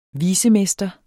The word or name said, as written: vicemester